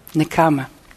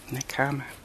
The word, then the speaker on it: Nekkhama: Parami, and the Nekkhama? The word renunciation itself? – Nekkhama. – Nekkhama, okay.